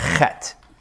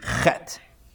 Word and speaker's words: Chet: Next letter, this is card number 10, is the letter Chet. Now, Chet is one of my favorite letters because you get to clear your throat every time you say it. Chet.